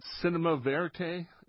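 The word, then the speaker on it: cinéma vérité: This is one of the greatest mixes of cinema verite.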